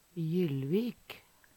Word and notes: Jyllvik: Numedalsmål

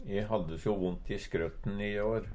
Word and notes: skrøtt: Numedalsmål

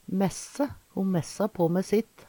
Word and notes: messe: Numedalsmål

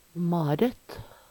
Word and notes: Maret: Numedalsmål

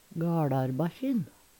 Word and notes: GaLarbakkjin: Numedalsmål

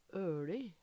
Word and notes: ØLi: Numedalsmål

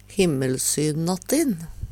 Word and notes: Himmelsynnattin: Numedalsmål

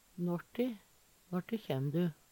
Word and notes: når` ti: Numedalsmål

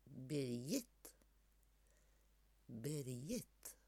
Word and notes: Berjitt, Birjitt: Numedalsmål

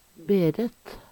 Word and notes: Beret: Numedalsmål

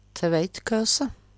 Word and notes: Tveitkøse: Numedalsmål; Veggli

